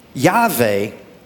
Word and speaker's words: Yahweh: You see, Yahweh, and I'm not mispronouncing that, in the Hebrew, it's the Yod, He, Vav, and He.